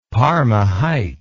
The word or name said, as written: Parma Heights